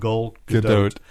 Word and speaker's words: Gal Gadot: I got to say it right, Gal Gadot. And it's even Gal, it's not Gal, it's Gal. Gal Gadot was announced as the star of Wonder Woman 2.